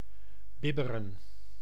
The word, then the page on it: trembler: Paris; /tʁɑ̃.ble/